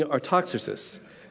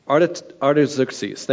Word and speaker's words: Artaxerxes: Artaxerxes. Artaxerxes, thank you.